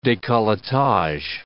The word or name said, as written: decolletage